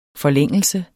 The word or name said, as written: forlængelse